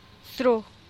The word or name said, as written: sru